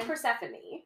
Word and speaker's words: Persephone: Is it Persephone? I can't even say it now. I can say it. – So I say Persephone.